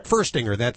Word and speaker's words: Furstinger: Furstinger.